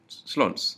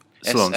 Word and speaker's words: Solondz: That's how you pronounce it. Solondz, yeah. – Yeah. Solondz. S O L O N D Z.